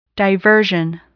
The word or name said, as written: diversion